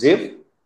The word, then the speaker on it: Ziv: it sounds as it's spelled, just Ziv.